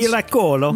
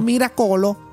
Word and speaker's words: Miracolo: Dat is La Vite e Come un Miracolo. Hopelijk zeg ik het goed in het Italiaans. – Miracolo. – Miracolo.